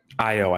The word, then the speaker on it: Ayo: You have Ao. Uh, am I saying her name right? – Ayo, I believe.